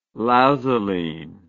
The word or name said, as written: lazuline